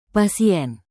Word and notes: pasien: Indonesian

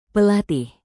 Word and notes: pelatih: Indonesian

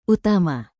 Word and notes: utama: indonesian

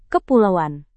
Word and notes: kepulauan: indonesian

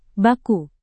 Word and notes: baku: indonesian

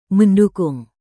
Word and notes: mendukung: Indonesian

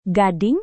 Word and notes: gading: indonesian

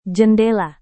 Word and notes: jendela: Indonesian

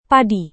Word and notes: padi: Indonesian

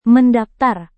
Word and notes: mendaftar: Indonesian

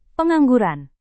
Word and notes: pengangguran: Indonesian

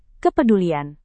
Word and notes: kepedulian: indonesian

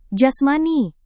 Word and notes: jasmani: Indonesian

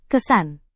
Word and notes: kesan: indonesian

